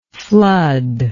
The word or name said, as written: floodlike